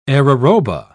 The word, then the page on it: araroba: British English